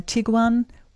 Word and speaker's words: Tiguan: Afternoon, I'd like to replace my Tiguan with a, is that how you say it? – Mm-hmm.